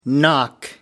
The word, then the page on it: knock: American English